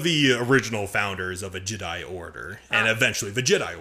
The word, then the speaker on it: Jedi: Not the Jedi Order, Jedi Order. – Yes. – Now I could go back to saying the proper Jedi instead of doing the emphasis on the die.